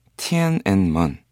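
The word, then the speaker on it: 티엔 앤 먼 (天安门): 깨알로제로의 노래 티엔 앤 먼. 네, 이 천안문이라는 네 중국어 발음이죠.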